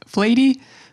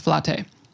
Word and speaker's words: Flatey: Now, I've said this name wrong many times. I walked around for days calling it Flatey, but I believe the correct pronunciation is Flatey.